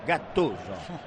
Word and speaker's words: Gattuso: Así es como lo pronuncia Guardiola, Gattuso. – ¿A qué cosa? – ¿Es así o no es así? – No lo pronuncias mal, tienes un acento del sur de Italia que me gusta.